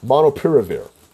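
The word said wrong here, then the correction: molnupiravir: It's Monlupiravir.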